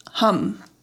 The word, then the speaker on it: Ham: It's spelled H-A-M, but it's pronounced hum, which I should have remembered that. Duh, hum. It's like humming.